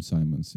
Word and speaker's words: Simons: Ah, I pronounce it Simmons. I still don't know. – I did my research. Some people call him Simmons. Some people call him Simons.